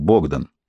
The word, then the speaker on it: Богдан: «Богдан», — прошептала она, сильно налегая на первый слог. Все поляки произносили его имя именно так, Богдан.